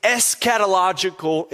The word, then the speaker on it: eschatological: But then as he talks about the prince of Persia, in the immediate sense, he begins to presume on his eschatological implications.